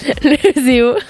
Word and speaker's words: ESEO: Pour rappel, aujourd'hui, on a parlé de l'ESOEEO. Non, j'ai comment on le prononce du coup ? – L'ESEO. – L'ESEO, parce que je le prononçais super mal.